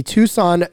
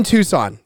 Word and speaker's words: Tucson: And the final game of the regular season will be against the Tucson Sugar Skulls. See, Said it right the first time. In Tucson.